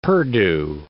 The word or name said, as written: perdu